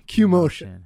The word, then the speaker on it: Q motion: How do you pronounce that? – Q motion.